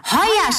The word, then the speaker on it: Heuer: Ach ja, Heuer schreibt man mit OI.